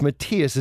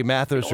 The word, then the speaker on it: Matthias: Okay, and I just to be sure, we're talking to it's Matthias. Is that how you pronounce this? – Yes. Okay. Matthias Jabs. – Because I wanted to say Mathis or something like that, but it is definitely Matthias.